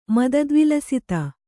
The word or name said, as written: ಮದದ್ವಿಲಸಿತ